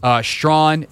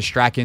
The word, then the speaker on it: Strachan: Strawn, a.k.a. the artist formerly known as Strachan until we all learned how to pronounce his name.